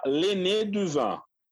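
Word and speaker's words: Le Nez du Vin: O mais famoso deles se chama Lené du Vin. Se escreve Lenez, N-E-Z, D-U, du, vin, vin. Lenez du vin, né? Lené du vin.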